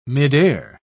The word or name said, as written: midair